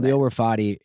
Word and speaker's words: Khalil Rafati: Khalil Rafati.